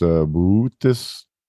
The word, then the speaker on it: Boötes: The constellation named Boötes is pronounced Boötes.